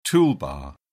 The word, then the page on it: toolbar: British English